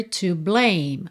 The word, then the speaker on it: blame: We've only got each other to blame. Mais um rarará ali, got, each other, got each other. To blame, termina só na letra M, esse E final não é pronunciado.